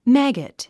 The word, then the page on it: maggot: mǽgət; マァゴット